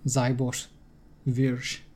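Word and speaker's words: Seyboth Wild: I think it's more Thiago Zabosz-Vuj, based on his own pronunciation on the ATP website. – I know. Zabosz-Vuj.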